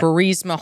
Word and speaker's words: Burisma: Burisma Holdings.